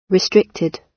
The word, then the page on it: restricted: British English